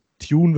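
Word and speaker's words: Thioune: Tune wird er ausgesprochen, Tione mhm. geschrieben, das verwirrt mich bis heute.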